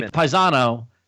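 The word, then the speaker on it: paesan: Okay, so Paisan is actually Paisano, and it's P-A-E-S-A-N-O. And but we dropped the last syllable because that's the dialect, and so it's paisan.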